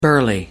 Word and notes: burly: American English

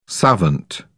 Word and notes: savant: British English

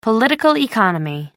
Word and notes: political economy: American English

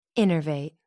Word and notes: innervate: American English

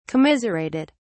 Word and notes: commiserated: British English

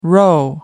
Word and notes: roe: American English

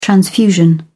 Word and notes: transfusion: British English

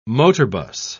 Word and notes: motorbus: British English

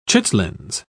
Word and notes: chitlins: British English

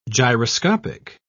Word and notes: gyroscopic: British English

